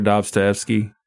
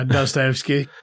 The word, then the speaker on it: Dostoevsky: Fyodor Dostoevsky. – Oh, yeah. – Is that how you pronounce that? – Yeah, Dostoevsky.